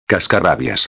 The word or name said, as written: cascarrabias